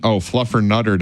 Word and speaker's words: Fluffernutter: And happy National Fluttermutter Day. – Excuse you? – Fluffermutter Day. – Nope. Try again. – Not for you? – You're still saying it wrong. – Fluffernutter Day. Fluffernutter Day. Happy Fluffernutter Day.